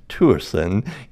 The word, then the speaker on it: Thuesen: And I think people are going to be very excited to meet Rasmus Thuesen, and it's pronounced Thuesen, even though when you see it, you will maybe scratch your head, but that's the enigma, the Danish language.